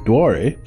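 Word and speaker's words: Doire: The Irish pronunciation, Dware, means oak woods.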